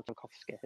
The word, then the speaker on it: Tarkowski: or Tarkowski, as it's pronounced,